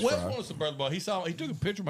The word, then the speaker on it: Burlebo: I pronounce it different, but Burlebo? – Burlebo. – Burlebo. Burlebo. So, Burlebo has some really awesome stuff.